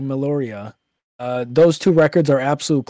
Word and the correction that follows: Meliora: Or how do you pronounce that album? Is that it? – Yeah, but I don't know. Meliora. – Yeah, okay. Yeah, Meliora. Okay.